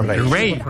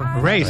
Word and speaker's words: Raye: Repite el nombre por si alguien nos ha quedado con ella. – Ray. – Ray. Se escribe Raye. – Raye con ella.